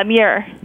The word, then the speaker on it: Muir: Yeah, Muir. – Muir. – So it's like mirror, but said really quickly.